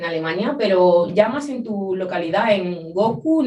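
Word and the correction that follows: Bochum: no sé si... – Bohum, es Bohum. Perdón, he dicho Goku como Dragon Ball. No, lo he pronunciado muy mal.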